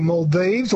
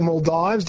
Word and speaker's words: Maldives: So we are obviously talking about the Maldives or Maldives, depending on how you want to choose to pronounce it.